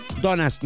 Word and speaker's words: Donut: Donuts, no, se escribe Donut. Y la pronunciación es donuts. La U como una A, pero es una U.